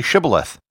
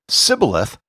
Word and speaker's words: shibboleth: If he said, 'Sibboleth,' because he could not pronounce the word correctly, they seized him and killed him at the fords of the Jordan. 42,000 Ephraimites were killed at this time." End quote. The Ephraimites couldn't pronounce the sh sound because their language didn't have it. When they tried to say shibboleth, they would say sibboleth.